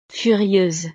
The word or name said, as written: furieuse